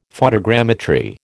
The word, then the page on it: photogrammetry: American English